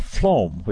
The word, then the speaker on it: phloem: it makes a tissue called phloem, which is spelled P-H-L-O-E-M.